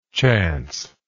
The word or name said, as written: chanceless